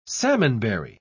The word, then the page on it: salmonberry: British English